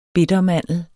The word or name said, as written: bittermandel